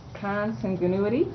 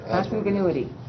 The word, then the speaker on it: consanguinity: Consan, how do you pronounce that? – Consanguinity? Yeah. – Consanguinity.